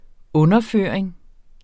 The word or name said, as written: underføring